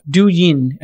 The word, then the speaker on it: Douyin: Douyin uh, is how it's pronounced, I believe, owned by ByteDance, collected 1.7 billion uh, in tipping revenue in the first half of 2020, according to my notes here.